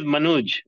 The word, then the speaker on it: Manuj: My name is Manuj Aggarwal.